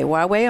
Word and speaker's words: Huawei: And I have to ask you, Huawei is spelled H-U-A-W-E-I. – Mm-hmm. – And you told me to say Huawei. Am I correct in that? – Huawei, yeah.